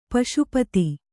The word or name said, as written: ಪಾಶುಪತ